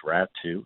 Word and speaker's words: Raty: One of them, who you named, believe it or not, his last name is pronounced Ratu, even though it's spelled R-A-T-Y.